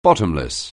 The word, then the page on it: bottomless: anglais